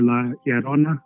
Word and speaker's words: La Llorona: In Mexican culture, La Llorona, which I don't pronounce that great because my Spanish is not amazing.